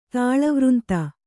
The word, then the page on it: ತಾಳವೃಂತ: tāḷavřnta